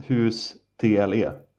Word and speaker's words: Hustle: Jaha, tet säger man kanske inte. Eller vad? – Nej, om det stavas hus TLE. – Ja. – Ja, Hustle. det verkar också vara någon basketfilm med Adam Sandler.